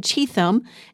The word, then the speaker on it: Cheatham: Apparently, I said Cheatham, and that was not correct, so I wanted to correct myself. It's Cheatham Memorial Methodist Church. I apologize for saying the name wrong yesterday. There's an H in it. The H is silent.